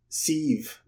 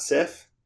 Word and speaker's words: Sif: I don't know because they say Bifrost, and they say Thor's wife is Siv, not Sif. They pronounce it like a V.